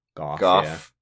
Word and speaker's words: Gogh: Goff. Yeah. Which I'm told that's also how you're supposed to pronounce Vincent Van Gogh.